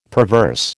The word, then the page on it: perverse: American English